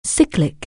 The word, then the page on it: cycle: anglais